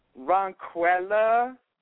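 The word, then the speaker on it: Ronquella: Ron Quella. – Ron Quella. How do you pronounce that? Or how do you spell that? – R-O-N-Q-U-E, and then the double L after that is the A.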